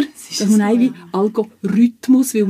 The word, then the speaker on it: Algorithmus: Und jetzt aber, zum wieso ist nochmal fixen, eigentlich die korrekte Aussprache wäre Algorithmus. Also eigentlich auf die Algorithmus, also auf die dritte Silbe betonen und das I sagen und nicht das Ü.